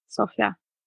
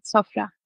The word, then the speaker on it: Sofra: Sofra. – Sofra. Aber ist die Betonung am Ende und man macht ein Rollenbiss? – Ja, in türkischen Wörtern ist die Betonung oft am Ende. Sofra.